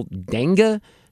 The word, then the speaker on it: dengue: So they say a new study estimates that 18.2 million Californians live in a region where conditions are likely suitable for local dengue transmission. That's how you pronounce it, like dengue fever or something like dengue? – That. Dengue, that's it. Thank you.